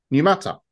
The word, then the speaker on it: Numata: It's Numata, like ammonia. Numata. There we go. We'll say that's what it's pronounced.